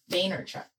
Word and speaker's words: Vaynerchuk: Gary Vaynerchuk. Is that how you say his name? – Vaynerchuk.